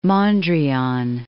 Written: Mondrian

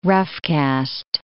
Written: roughcast

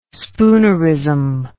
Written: spoonerism

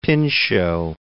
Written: Pinchot

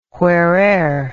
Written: whereer

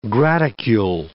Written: graticule

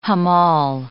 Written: hammal

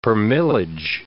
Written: permillage

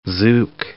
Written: zouk

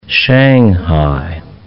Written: shanghai

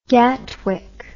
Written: Gatwick Airport

